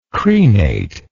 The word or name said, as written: crenately